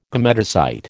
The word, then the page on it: gametocyte: American English